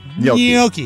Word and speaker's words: gnocchi: Is that the right pronunciation? Or is it gnocchi? – Is it gnocchi? – Gnocchi, yeah.